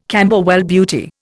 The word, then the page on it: Camberwell Beauty: Indian